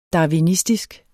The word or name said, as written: darwinistisk